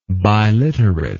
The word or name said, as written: biliterate